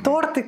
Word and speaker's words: торты: Э, торты, класть. Э, это правильные слова, неизвестные этому эфиру до сегодняшнего дня.